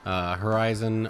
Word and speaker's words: HRZN: Horizon, or is, I think that's how they're saying it. It's spelled HRZN.